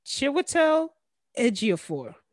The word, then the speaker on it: Chiwetel Ejiofor: Benedict Cumberbatch, Elizabeth Olsen, Scarlet Witch, Benedict Wong, Rachel McAdams, Chiwetel Ejiofor. – Jesus Christ. – I said it right? – Say it again. – Chiwetel Ejiofor.